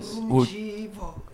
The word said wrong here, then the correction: ruzivo: Knowledge is Ruzivo. – Ruzivo. – Knowledge. Ruzivo. – Ruzivo. – Yes. So like your VH, it's a B.